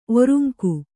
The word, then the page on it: ಒರುಂಕು: Kannada